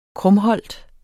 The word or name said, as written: krumholt